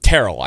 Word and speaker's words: Terrell: Terrell, I believe, is how it's actually pronounced.